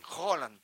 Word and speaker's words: Haaland: Claro, Haaland es dice, Holland. – Como si dijeras un queso, ¿no? – ¿Sabes qué pasa el martes?